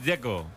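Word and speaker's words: Džeko: A mí el que me parece difícil es el de Checo, Dzeko. Porque DZ, ¿cómo se pronuncia? – Dzeko. Bruno Dzeko. Dice que es Dzeko. – Como tirando a J, Dzeko o algo así.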